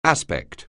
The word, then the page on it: aspect: anglais